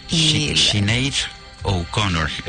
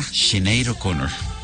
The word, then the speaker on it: Sinead O'Connor: Sinead O'Connor es lo que, ¿sí?, lo que me dicen aquí las los verificadores de pronunciación. – Pues ahí está. – Sinead O'Connor.